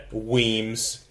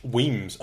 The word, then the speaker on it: Wemyss: Weems. Get out of here with your pronunciations. – Oh, yeah. – Of English. Weems. Oh, I guess Wemyss. Ooh, it's not Wemyss.